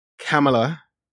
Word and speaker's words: Kamala: Beto O'Rourke, Peter Buttigieg, Joe Biden, Elizabeth Warren, Kamala Harris, which is how we're supposed to pronounce her name.